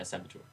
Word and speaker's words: saboteur: a Ravager and a Commando, you've got a Medic, you've got a Synergist, and you've got a Saboteur. I think that's how you say it. – Saboteur? – Yeah. – Okay, yeah. – It's the thing that sabotages stuff. – Yeah, Saboteur.